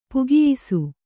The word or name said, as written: ಪಕಾಯಿಸು